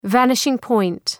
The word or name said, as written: vanishing point